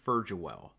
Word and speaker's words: Furgiuele: My last name is Furgiuele. – Wow, Furgiuele. – Yes. – All right. It's the everyone goes with the hard G, the Furg.